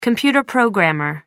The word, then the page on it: computer programmer: American English